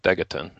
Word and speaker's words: Degaton: I like how there's an editor's note here, but it's just to tell us how to say that it rhymes with megaton. So actually, I was saying it wrong, Degaton.